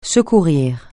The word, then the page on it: secourir: francais